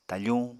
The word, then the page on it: tafuñ: Apatani